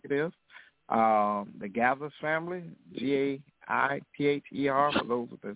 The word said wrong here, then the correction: Gaither: It's G-A, Gaither, how you pronounce it? – Gaithers. – Gaithers, thank you, Gaithers.